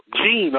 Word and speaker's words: Jean: Jean, or however you want to pronounce it in English. He pronounces it as Wyclef Jean like it's pronounced in French.